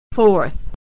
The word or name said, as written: fourth